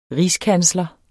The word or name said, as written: rigskansler